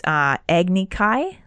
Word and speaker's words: Agni Kai: And the only way to resolve that is Agni Kai.